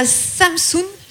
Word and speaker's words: Samsun: tu es née à Samsun, si je le dis bien.